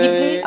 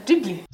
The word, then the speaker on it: Ghibli: Studio Ghibli. Rozmawiają z Kina Kosmos i krytyczka filmowa. – Odcinek nazwaliśmy Studio Ghibli nieprzypadkowo. – E, Ghibli, a może Ghibli.